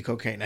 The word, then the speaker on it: Cokayne: G.E. Cocaine, I'm sure that may be pronounced different, but it's spelled. – Cocaine, yeah. – But it's spelled. C-O-K-A-Y-N-E. So, I'm going to say G.E. Cocaine.